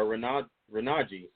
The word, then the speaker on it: Renogy: Yeah, the next item we've got is from the same company, which is Renology, or Renogy, sorry, wrong pronunciation.